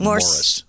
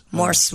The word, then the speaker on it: Morse: You know, I think because of your Morse code talk, I think my tinnitus has become Morse code at night. – It's Morse code, not Morris. – Morse, whatever.